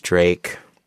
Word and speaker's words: Drake: His name is Drake. Rhymes with fake.